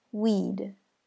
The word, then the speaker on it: we'd: Weed. Weed. Weed. It's pronounced just like the word weed, which is spelled out W-E-E-D.